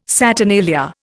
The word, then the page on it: saturnalia: Indian